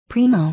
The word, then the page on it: primo: príːmou; プリーモゥ